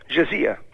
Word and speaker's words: jizya: Pagando una tassa di assoggettamento, che in arabo si pronuncia jizya,